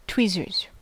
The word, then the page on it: tweezers: American English